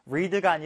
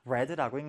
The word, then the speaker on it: read: 철자는 동일하지만 read가 아니라 read라고 읽는다.